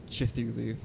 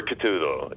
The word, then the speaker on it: Cthulhu: I usually go for Cthulhu. You know, it's there's no A between the C and the T, you know, where I think a lot of people seem to kind of just blend it all in. So I think it's Cthulhu. You know, it's very quick.